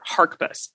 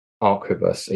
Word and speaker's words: arquebus: Am I supposed to pronounce the H? – Good question. I don't think we know the answer to that one. You can often infer pronunciation, as you will know, you can infer pronunciation from spellings often and how they change. I always say arquebus, even if it has an H. So for me, the H is silent, but that's by no means certain.